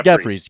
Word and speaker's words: Jeffries: So, so you say it like a G. – Okay. – With Ge- a G in it. – Gotcha. So Matt Matt Jeffries, right? Jeffries? – Jeffries.